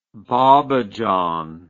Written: bobbejaan

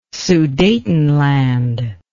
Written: sudetenland

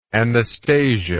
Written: anastasia

